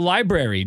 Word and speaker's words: library: How you say it. – Library. – The R got left where February got left theirs.